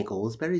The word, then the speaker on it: Goldsberry: Renee Goldsberry? Is that how you say her name? Mm-hmm. From Hamilton? – Yes.